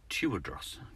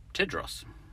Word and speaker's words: Tewodros: I used to think he was called Tewodros because it's spelt T E W O D R O S. But somehow that translates as Tedros.